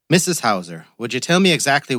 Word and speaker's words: Heuser: Mrs. Hauser, Detective. H-E-U-S-E-R. But it's pronounced Hauser.